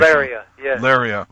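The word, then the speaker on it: Lereah: Lira. I don't know if that's the correct pronunciation. – Laria.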